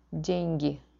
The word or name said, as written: dengi